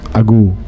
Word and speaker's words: Ago: Mais le Ago, quand tu le prononces Ago, si je ne me trompe pas, vers le bas, le go, ça veut aussi dire attention.